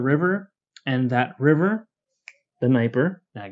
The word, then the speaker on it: Dnieper: So by the 10th century, the largest settlement is going to be along the Dnieper, with a silent D there, I may or may not have had to YouTube how to pronounce that because I had forgotten.